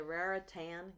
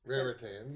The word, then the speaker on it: Raritan: Raritan, I can't say that either, river. – Raritan, you said it right, I think.